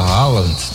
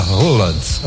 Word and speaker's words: Haaland: Durante os jogos do Brasil, esse último aqui da Noruega chamou a atenção porque o próprio Erling Haaland, falava que o nome dele não era Haaland, era Roland. – É a pronúncia é essa última aí.